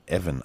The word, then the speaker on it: Evan: Also Ian, Mc, äh, Ian, äh, Ian, oder Evan eigentlich ausgesprochen.